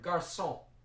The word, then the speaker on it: garçon: It's a mark that looks essentially like a comma placed beneath the letter C in the spelling of French, Portuguese, and Spanish to indicate that the letter is to be pronounced S, as in the French word garçon.